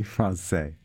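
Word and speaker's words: Françaix: The composer's last name I have heard pronounced Francais and also Francais, and apparently the composer said he was tired of correcting people, so he just went with the flow and said, just call me Francais.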